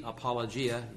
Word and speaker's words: apologia: Just make that G, gamma, soft G, apologia, you would read.